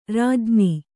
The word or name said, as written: ರಾಜ್ಞಿ